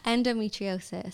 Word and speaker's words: endometriosis: But then you spoke about like your health, and how did you find out, like, I need to say the name right, endometriosis? – Close. Endometriosis. So I have endometriosis and polycystic ovaries. Polycystic ovaries is like lots of little cysts on your ovaries. That doesn't really affect me. The endometriosis ruined my life at one point.